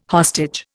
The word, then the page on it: hostage: Indian